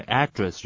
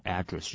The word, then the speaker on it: address: 这边的 address 就是 email address，就是你的电子邮件信箱。好，那 address 这个字呢，地址，对不对？你可以念成 address，重音在前面，或者是 address，都是可以的。address 或者是 address 当名词地址的时候使用，好，记起来。